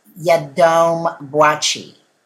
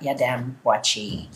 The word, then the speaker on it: Yiadom-Boakye: Her name is Lynette Yadom Boachi.